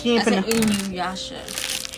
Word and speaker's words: Inuyasha: She said Inuyasha. – She ain't pronounced. – Inuyasha. – She pronounced it right this time.